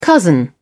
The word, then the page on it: cousin: British English